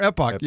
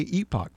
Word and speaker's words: epoch: Whether you pronounce it epoch or epoch. Epoch. Some of the people who work for the paper will say epoch, folks, but it is not epoch. There is one correct pronunciation. It's epoch.